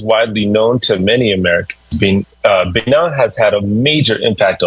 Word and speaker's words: Benin: But first, today we're headed to Benin, or Benin, as it's pronounced in French, a country in West Africa with deep history and powerful connections to the Black diaspora.